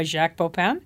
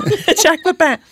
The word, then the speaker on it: Jacques Pépin: Jacques Pépin.